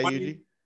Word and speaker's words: Yuji: I hope I'm saying his name right, but still. – Yeah, Yuji.